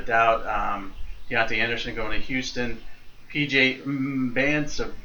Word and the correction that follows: Mbanasor: I always screw this up. Mbanasor. – Mbanasor. I remember Jason Howell telling us the great story of how he pronounced his name. He goes, Mbanasor.